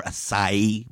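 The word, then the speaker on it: açaí: You know, we've all seen a mouth-watering healthy smoothie or acai bowl. I can never say that word right. – You said it. I think you said it right.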